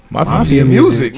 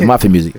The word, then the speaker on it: Mafia Music: Mafia music. – Yo, am I tripping? I said it right. – I didn't say it. – Oh yeah. Mafia music.